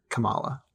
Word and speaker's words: Kamala: I'm really self-conscious that I'm saying her name wrong or something. – I know. – Somebody please correct me. – I'm sorry for saying it multiple ways. I think it's Kamala. – You guys are right. There was a video where she pronounces it because this came up in an interview recently.